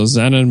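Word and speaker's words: Džanan: Zanon probably is the pronunciation. – Okay, so Zanon Musa.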